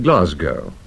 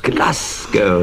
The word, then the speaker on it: Glasgow: He'd been listening to the news, the 9 o'clock news, and he'd heard me mispronounce what is one of his, what shall I call it, hobby horses. I had said Glasgow. – Glasgow, yes.